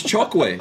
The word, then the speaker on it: Chokwe: It's Chokwe.